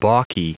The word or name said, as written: balky